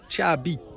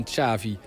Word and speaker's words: Xavi: Ja, zijn naam wordt dus uitgesproken als Xavi in plaats van Xavi.